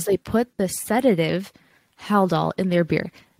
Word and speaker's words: Haldol: I hear you saying Haldol, but to me I'm hearing hell doll.